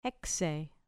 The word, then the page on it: hexei: grec